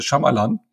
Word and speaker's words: Shyamalan: Night Shyamalan. Äh, Shyamalan. Das habe ich gleich sofort falsch gesagt. – Ja. Wo ja das Y stumm ist.